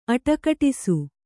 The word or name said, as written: ಅಡಗಟ್ಟಿಸು